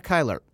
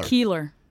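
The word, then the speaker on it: Keilar: First, we have Brianna Kyler. Did I say that right? Is it Keeler? – Keeler.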